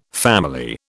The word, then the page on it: family: British English